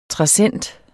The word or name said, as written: trassent